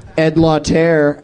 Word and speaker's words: Lauter: Ed Lauter, and I'm pronouncing it right.